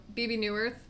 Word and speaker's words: Bebe Neuwirth: Is it Bebe? – Uh-huh. – Bebe Neuwirth? – Yes. – Who is the aunt.